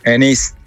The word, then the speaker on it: Enix: ¿Qué es, Enix o Enix? – Enix. – Enix, vale. – Lo digo bien, ¿no? – Lo has dicho bien. Terminado en X.